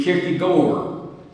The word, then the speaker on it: Kierkegaard: He had the unusual name of Soren Kierkegaard, though I have been assured by my colleagues that the Danish pronunciation would be Kierkegaard.